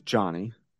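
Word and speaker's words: Jhony: But Johnny Brito, is that how you say it? Or is it Johanny? – Oh, it's Johnny. – Johnny Brito.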